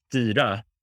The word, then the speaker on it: Styra: So it's pronounced Styra in Swedish, which means pretty much steering or to govern or to steer.